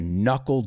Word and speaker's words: knuckle: Then I said, it's time to knuckle down. This is a good word because knuckle is nudillo. It's a word just like knife that has a silent K before the N, like the word no.